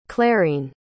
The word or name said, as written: clarine